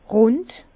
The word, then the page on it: rund: Bas-Rhin; Herrlisheim